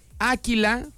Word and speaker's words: Áquila: Hoy día de San Áquila, porque lleva acento en la A, órale.